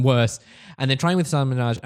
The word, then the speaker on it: Hasan Minhaj: And Patriot Act with Hasan Minhaj, as it's meant to be pronounced, as so I'm told, which is sort of Netflix's newest attempt at a political talk comedy show.